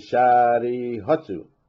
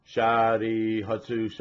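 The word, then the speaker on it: shari hotsu (舎利弗): So when you, where you would want to say two syllables, shari, and then hotsu, right, to the beat, what you actually say is shari hotsu. See, there's only one beat above the word hotsu. So it would be pronounced shari hotsu, and so on.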